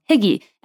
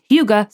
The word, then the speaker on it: hygge: It is called H-Y-G-G-E. It looks like it's spelled Higgy.